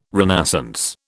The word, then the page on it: renascence: British English